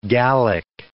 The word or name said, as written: Gallic